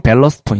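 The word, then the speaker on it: Ballast: 이런 식으로 발라스트 포인트. 네. – 네. 정확히는 밸러스트 포인트입니다.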